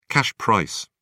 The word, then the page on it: cash price: British English